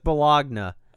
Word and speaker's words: Bologna: I think it's pronounced Joseph Bologna.